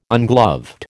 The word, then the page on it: ungloved: American English